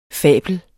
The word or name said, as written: fabel